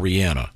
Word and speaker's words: Rihanna: Is it pronounced Rihanna or Rihanna? – Rihanna. – Rihanna. – That's what I thought.